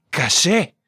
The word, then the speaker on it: caché: Se dice caché, igual que en francés. – ¿Y cómo se escribe? – C-A-C-E.